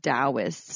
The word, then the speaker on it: Taoist: It's spelled Taoist, but it's pronounced Taoist. – So FYI, if you think like me, Tao.